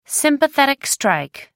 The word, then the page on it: sympathetic strike: American English